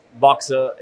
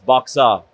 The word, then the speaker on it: BOXR: But the way we pronounce it in Australia, different to the Americans, is not BOXR, it's BOXR.